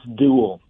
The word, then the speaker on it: Duell: It's Duell. – Duell. – So just like a sword fight.